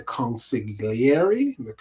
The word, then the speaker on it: consigliere: So anyway, Snaps meets with these bankers, and Anthony is there with him, and Anthony, acting as the legal mind, the consigliere, is properly how that's properly pronounced. Acting as a consigliere,